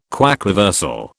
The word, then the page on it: quaquaversal: British English